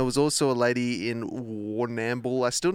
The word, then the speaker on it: Warrnambool: I think it's Warrnambool. – Warrnambool. – It is.